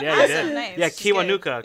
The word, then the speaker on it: Kiwanuka: Michael How do you say it? I don't want to say it bad. – Isn't it Kiwanuka? – Michael Kiwanuka. – Yeah, I was right.